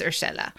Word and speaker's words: Urshela: I think it's Urshela.